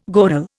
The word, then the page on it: goral: Indian